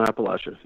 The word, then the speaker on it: Appalachia: Appalachia? – Yep. On Appalachia.